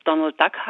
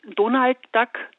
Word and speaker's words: Donald Duck: Amerikanisch würde es natürlich Donald Duck heißen. Im Deutschen hat sich Donald Duck eingebürgert, was ja eigentlich eine Mischung aus beiden ist. Und Donald Duck ist eine ganz spezielle Aussprache, vor allem von den Donaldisten. Die Donaldisten betrachten die Welt von Entenhausen mit wissenschaftlichen Methoden anhand der Übersetzung von Erika Fuchs und betrachten dann Duck als deutschen Familiennamen.